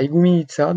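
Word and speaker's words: Igoumenitsa: Donc en 2017, tu es parti de Venise en Italie et pour arriver en Grèce, à Igouminitsa, je ne sais pas si je prononce bien. Donc tu es parti avec deux amis euh, et, vous avez fait plus de 1000 km, je crois, en une quinzaine de jours, c'est ça Et oui, on est parti de Venise, on est, on allé au nord de la Grèce, à Igouminitsa, donc tu l'as bien prononcé.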